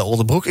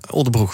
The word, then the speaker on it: Oldebroek: SGP-gemeenteraadslid bij Oldebroek. Ik leg steeds de klemtoon verkeer denk ik, hè? Oldebroek, Oldebroek. Ja, buitenstaanders zeggen meestal Oldebroek, maar wij zeggen gewoon Oldebroek.